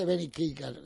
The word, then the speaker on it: Ben E. King: Es que se dice Benny King, la pronuncia casi Benny King.